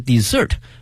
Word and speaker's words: dessert: 那么还有一个词呢 d-e-s-s-e-r-t 多了一个 s, 它的发音是 desert, 它是指呢我们去点快餐的时候呢会有一些小的甜品，比如说像奶昔啊冰激凌啊等等，那么它通称为 dessert 啊，甜品。